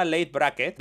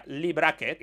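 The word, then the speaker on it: Leigh Brackett: se llama Lady Brackett, bien pronunciado, sería Lee Brackett, es una autora que es una escritora de ciencia ficción.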